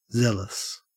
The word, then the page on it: zealous: Australian English